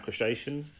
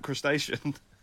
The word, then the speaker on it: Cretaceous: Speaking of Jurassic World, have you watched any of the new Camp Cretaceous? – Cretaceous. I was the Camp Crustacean.